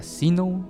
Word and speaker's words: Zeno: la aplicación es Seno Radio con Z. Seno, se pronunciaría Sino, Zeno.